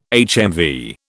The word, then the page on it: HMV: British English